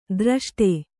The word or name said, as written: ಧೃಷ್ಟೆ